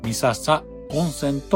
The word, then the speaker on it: 三朝温泉: 三度朝を迎えると元気になると言われる温泉で、そのため三つの朝と書いて三笹温泉と読みます。